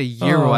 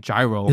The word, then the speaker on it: gyro: Euro? – Yeah. – I think I would stutter if I tried to say euro as opposed to saying gyro.